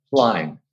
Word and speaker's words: Schlein: Schlein.